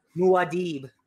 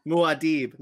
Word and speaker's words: Muad'Dib: So the first book of Dune, Book of Muad'Dib or whatever it pronounced that. – Muad'Dib. – Muad'Dib. Now I know.